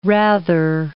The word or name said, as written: rather